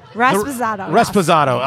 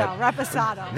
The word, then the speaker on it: Reposado: We're about to get to the, what are we getting to, the Raspado? – Reposado. – Oh, yes. See, I can't even pronounce it. – Reposado.